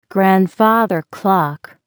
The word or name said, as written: grandfather clock